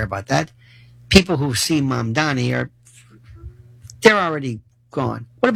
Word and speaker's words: Mamdani: We have this mayor, Zoran Mamdani, okay? And his name is still not pronounced. It's Mom, like Mom and Dad, Donnie, like Donny Osmond, Mamdani.